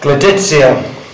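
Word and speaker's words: Gleditsia: All right. Gleditia. That's how you pronounce it. – Oh. – Says my phone.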